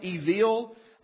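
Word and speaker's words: evil (אויל): There's another word for fool in Hebrew, and you could truly pronounce it as evil. You would spell it E-V-E-E-L, evil.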